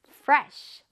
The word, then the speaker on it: fresh: Fresh, 바로 신선한이란 뜻이에요. 냉장고에 저 과일들이 굉장히 신선해 보이지 않나요? Fresh, 한번 따라해볼게요. Fresh, fresh이 아니에요. F 발음 잘해줘야 돼.